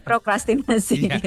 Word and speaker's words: prokrastinasi: Enggak usah dikasih jeda sebenarnya Kak. Jadi bukan prokrastin, bukan tapi prokrastinasi.